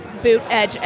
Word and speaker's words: Buttigieg: Yes, it's right, Buttigieg. – Someone is putting out misinformation about how to pronounce his name. – Well, he's got a T-shirt that says Buttigieg.